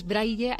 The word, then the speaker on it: Braille: Es Braille, hay que decirlo tal cual se escribe, con doble L.